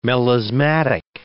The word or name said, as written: melismatic